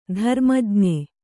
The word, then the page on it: ಧರ್ಮಜ್ಞ: dharma jña